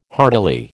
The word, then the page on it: heartily: American English